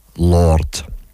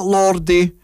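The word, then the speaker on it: Lorde: And this cover is performed by a girl called Lord. Not Lordy, and I have been pronouncing that incorrectly for years, so Lord, I apologise now.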